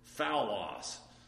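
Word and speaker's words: phaulos: Phallos is the Greek word there. What does that sound like? Foul. That's our English word foul is transliterated from that.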